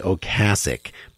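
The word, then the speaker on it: Ocasek: Well, until preparing for this interview, my entire being a fan of him, which makes me also think about Rick Okasik, whose name is actually Ocasic, but he doesn't care how you pronounce it.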